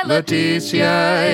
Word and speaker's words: Letizia: L-E-T-I-Z-I-A. – Do I pronounce that fifth way? – Letitia is the Queen's first name.